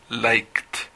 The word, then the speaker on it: liked: Porque like no se tienen que fijar en cómo se escribe la palabra, sino tienen que fijarse en cómo se pronuncia la palabra y cuál es la última pronunciación de like. No es la k, por supuesto. Y k es un sonido que está en la número 1, en el recuadro número 1, por ende, se pronuncia liked.